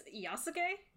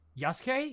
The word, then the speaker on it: Yasuke: I believe it's Yasuke. – Yasuke? Yeah. Yasuke? Like Sasuke? Yasuke? Something like that.